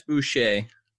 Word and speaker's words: Boucher: Chris Boucher. – Boucher, yeah, because I always think of Bobby Boucher from that movie.